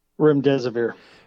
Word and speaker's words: Remdesivir: Protocol was to give COVID-19 patients, how do you pronounce this? Remdesivir? Is that what it is? – Remdesivir.